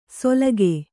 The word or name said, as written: ಸೊಲಗೆ